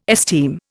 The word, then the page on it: esteem: Indian